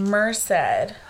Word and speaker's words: Merced: Merced. – Okay. – I said it right. – Merced.